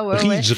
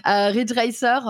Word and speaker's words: Ridge Racer: Parce que j'avais ah Rage Racer en démo comme ça. – Ridge, Ridge. – Ridge, pardon, ouais. Ridge Racer, avec ma prononciation excellente.